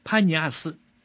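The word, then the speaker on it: Paneas: 希腊人呢，叫他潘尼亚斯。但是呢，阿拉伯人对坡这个发音啊有些困难，所以呢，他们就把潘尼亚斯啊说成班尼亚斯。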